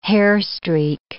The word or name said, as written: hairstreak